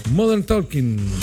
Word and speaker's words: Modern Talking: De Modern Tech, Modern Talking, ¿cómo se pronuncia? Modern Talking.